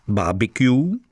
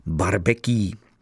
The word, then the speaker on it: barbecue: Tak to se vyslovuje buď po anglicku barbecue, anebo po francouzsku barbecue.